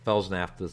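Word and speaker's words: Fels-Naptha: It's spelled Fels, F-E-L-S, then hyphen Naphtha, N-A-P-T-H-A. That's Felsnafta soap.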